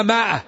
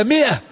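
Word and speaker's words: مائة: لا يقرأ القرآن إلا مع شيخ، لأنك تجد العام الآن يقولون ماء، ما هي ماء، هي مئة، الميم مكسورة، ولكن في الإملاء العربي هذه الميم تمد بألف ويوضع عليه دائرة أنه لا ينطق.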